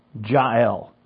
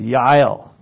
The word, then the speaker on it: Jael: But he ends up at this lady's tent, Jael, or Yahel. You know, they wouldn't pronounce the J as a J. Like a Y, they pronounce it, Yael.